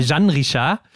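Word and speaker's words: Jeanne Richard: Wir wurden ja auch darauf hingewiesen, Hendrik, dass Jean Richard nicht Jean Richard ausgesprochen wird, mhm. sondern Jean Richard.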